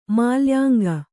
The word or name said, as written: ಮಾಲ್ಯಾಂಗ